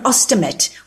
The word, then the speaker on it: ostomate: So you're an ostomate. Ostomate. Yes. Is that the correct way of pronouncing it? – That's the correct word. Yes. Ostomate. Okay.